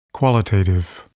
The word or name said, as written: qualitative